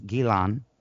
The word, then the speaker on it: Ghislaine: It was also during the early 90s that he became romantic with, as people call her, Ghislaine. Her real name is Gilan. That's how you pronounce it in whatever the fuck the language is.